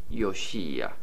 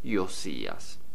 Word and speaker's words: Josías: Su nombre en hebreo se pronuncia Yoshía y en griego Yosías.